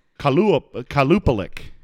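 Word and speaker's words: Qalupalik: It is spelled Qualupalik. – Qualpica? Kelpica? – I'm going to show this to you. – Okay, let me see. The Kalupalik. – Yes.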